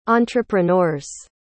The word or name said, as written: entrepreneurs